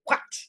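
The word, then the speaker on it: hwæt: So the first word of Beowulf is a word that is called what, and it's sort of pronounced like cat and what combined with each other.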